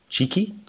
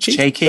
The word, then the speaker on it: Txiki: Is that T-X-I-K-I? Cheeky Bergenstein? Is it Cheeky? – Bergenstein.